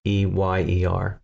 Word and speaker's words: DeMeyer: It's DeMeyer, like Oscar. With a D in front DeMeyer. – Of it. Yeah, DeMeyer.